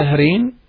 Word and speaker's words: Zahreen: In English, any spelling that will make you pronounce it Zahreen. So, for example, Z A H R double E N. Z-A-H-R-E-E-N, Zahreen.